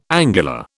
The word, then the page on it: angular: British English